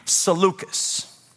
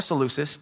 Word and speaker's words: Seleucus: Number three, Seleucus. Or Seleucus, depends on how you want to pronounce that.